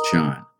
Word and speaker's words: Chan: That's the Japanese pronunciation. The Chinese pronunciation is Chan.